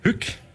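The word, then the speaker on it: Hük: Hück. – Hück. – H-Ü-K. Hück.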